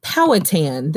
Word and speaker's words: Powhatan: Powhatan.